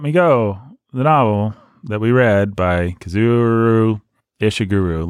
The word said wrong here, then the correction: Kazuo: How do you say it? – Kazuo Ishiguro. – There you go. It's kind of like it's spelled. K-A-C-U-O.